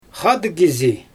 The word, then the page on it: xaadɯɣ kizi: Xyzyl